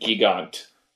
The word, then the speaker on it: Gigant: I think the Japanese would have pronounced it Gigant.